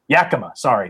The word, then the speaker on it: Yakima: The Moxie Washington is right in the middle of that Yimica. I think I'm saying that right. Yakima, sorry.